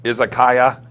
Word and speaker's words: Izakaya: I don't know if I'm pronouncing this right. Modern Izakaya? – Oh, yeah.